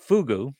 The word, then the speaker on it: fogou: F-O-G-O-U. – Oh, Fogu definition. – They actually have a definition? – A Fogu, pronounced Fugu.